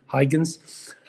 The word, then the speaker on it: Huygens: And around that time, there was a Dutch scientist, Christian Huygens, or as we like to pronounce it in English, Huygens.